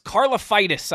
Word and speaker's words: Karlaftis: It's Karlaftis, I believe is how he pronounces his last name.